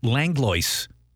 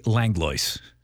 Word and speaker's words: Langlois: Langlois means the Englishman in French, and my family migrated south from Nova Scotia, but Langlois is the way I pronounce it. We Americanized it. My mamay and papé didn't appreciate that, but we say Langlois.